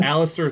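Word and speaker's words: Aleister: how do you say his name? – Alistair Black. – Alistair, thank you.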